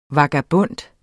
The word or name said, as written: vagabond